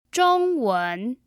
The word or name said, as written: zhongwen